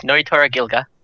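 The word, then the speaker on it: Nnoitora Gilga: I was going to mention is Kenpachi from Bleach faces Notoa Gilga. Did I say that right? – My man really just put another hand on his sword. Yes, Noitora Gilga. That's right.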